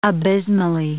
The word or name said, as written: abysmally